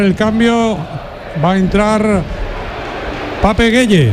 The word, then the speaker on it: Pape Gueye: le pregunté y me dijo la pronunciación correcta, que es Pape Gueye.